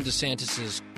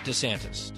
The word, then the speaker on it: DeSantis: So I had seen this, like, some old videos of Ron DeSantis's political career. He introduces himself as Ron DeSantis. And I've always called him Ron DeSantis, just like sort of not drawing out the E, just making it quicker, DeSantis.